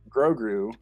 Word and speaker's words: Grogu: That's Grogu.